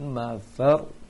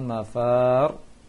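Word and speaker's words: mafar (المفر): Ini bacanya yaqulul insanu yawma idzin ainal mafar. Jangan mafar, terbaca tadi ya, Farel sama Zahra membaca panjang tadi mafar. Seharusnya langsung fa-nya langsung nempel ke ro, karena fa tidak panjang.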